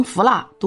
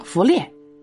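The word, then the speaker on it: 伏腊: 李林甫曾引荐萧民为户部侍郎，萧民不学无术，有一次与中书侍郎严挺之一起去参加婚丧之事，竟然把《礼记》中的一句“ 征长服蜡” 读成“ 服猎”。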